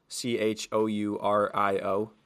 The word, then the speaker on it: Chourio: Shor- Chourio, C H O U R I O. – And you gotta say it confidently.